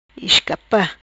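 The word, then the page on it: Iscapa: Patuá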